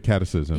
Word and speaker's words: catechism: The catechism.